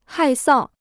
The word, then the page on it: 害臊: hài sào